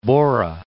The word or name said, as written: Borah Peak